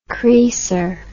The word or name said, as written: creaser